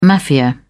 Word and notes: mafia: British English

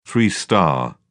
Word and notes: two star: British English

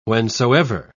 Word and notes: whensoever: British English

